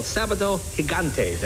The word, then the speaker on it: Sábado Gigante: It's called Sábado Gigante. Did I say that right, Marielena? Yeah? Okay.